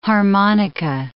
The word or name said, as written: harmonica